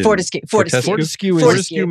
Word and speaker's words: Fortescue: Fortescue Metals.